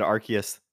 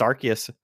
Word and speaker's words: Arceus: You know, I love that you you said Arceus the right way, because it's been Arceus and Arceus going back and forth. But if you watch any of the Pokemon movies, like, they say his name, it's Arceus.